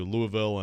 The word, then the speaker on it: Louisville: But like when they got to the, like, 86 Final Four, and by the way, they lost to Louisville and Never Nervous Purvis. – Great pronunciation of Louisville by you.